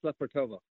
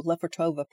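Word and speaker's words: Lefortovo: Oh, um, it's Lefortova. – Ooh, Lefortova prison.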